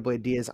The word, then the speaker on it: Blayde Diaz: You can find me at Ricardo Blayde Diaz on TikTok and Instagram.